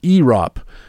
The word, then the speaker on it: ERAWP: And by the way, I multiplied ERA and WHIP to make a new metric called ERAWP, pronounced EROP, and I took the top 50 EROP relievers, leaving out closers.